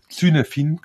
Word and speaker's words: Cynefin: Auch hier würde ich mal empfehlen, wenn irgendjemand weiterlesen will, mal zu recherchieren, zu googeln nach dem Begriff Cynefin, also schreibt mir dann C-Y-N-E-F-I-N, Cynefin quasi, aber wird Cynefin ausgesprochen.